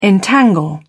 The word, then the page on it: entangle: British English